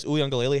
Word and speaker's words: Uiagalelei: The game was still very close because Uiagalelei had an unreal showing for that backup quarterback good pronunciation. Role. That slipped right off the tongue.